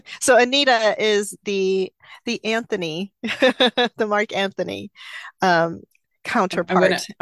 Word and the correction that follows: Antony: I'm gonna stop you because you're saying it wrong. It's Antony. – Anthony, very sorry, you're right. There is no H.